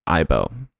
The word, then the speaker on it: Aibo: Aibo.